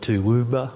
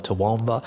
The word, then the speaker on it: Toowoomba: People call it Toowoomba or Toowoomba.